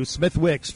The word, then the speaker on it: Smithwick's: It actually has a W, Smithwick's, but it's right. They pronounce it Smithwick's.